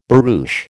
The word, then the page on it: barouche: American English